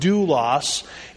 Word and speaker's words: doulos: This is translated from the Greek word, and we have the Greek word there, doulos, pronounced doulos.